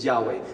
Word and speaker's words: Yahweh: So it's our best guess at how to pronounce it, is Yahweh.